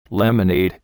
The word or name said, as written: lemonade